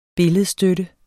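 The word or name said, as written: BILLEDSTØTTER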